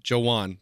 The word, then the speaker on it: Juwan: Joe, J-O-E, Juan, right? – Yeah, that's how it's spelled. Juwan. Juwan.